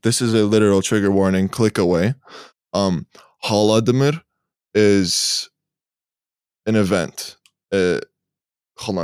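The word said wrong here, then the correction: Golodomor (Голодомор): I pronounced it wrong because it's written in English, but it says it in Russian. Golodomor.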